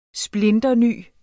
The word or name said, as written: splinterny